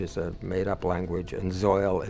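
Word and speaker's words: Zeuhl: And I don't know if everyone pronounces Zoil like that. It's Z-E-U-H-L. I pronounce it Zoil.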